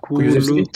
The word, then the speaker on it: Kulusevski: Euh, oh purée, j'arrive pas à prononcer son nom, mais la nouvelle recrue de Tottenham, Kulusevski.